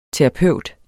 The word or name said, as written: TERAPEUTEN